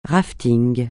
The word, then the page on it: raft(ing): francais